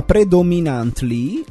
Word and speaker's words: Predominantly: Ha consigliato una redattrice della radio, Beatrice Dusi, che salutiamo, e si chiama Predominantly, la mia pronuncia sì. – è giusta, vero prof di inglese,